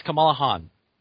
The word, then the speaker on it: Kamala Khan: It's Kamala Khan. – Even though it looks like it's spelled like the same as the Star Trek villain, it's not pronounced that way.